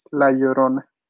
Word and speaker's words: La Llorona: Whenever you see a double L together, You have to pronounce it like a Y. It's La Llorona.